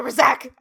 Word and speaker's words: Razak: The Razak! I think the emphasis is on the second syllable.